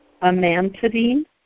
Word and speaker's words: amantadine: Amantadine amantadine.